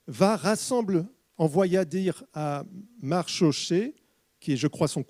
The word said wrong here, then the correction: Mardoché: cousin, je ne sais pas si je prononce bien, Mardoché, voilà, vous prononcez mieux que moi.